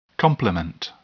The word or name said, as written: complement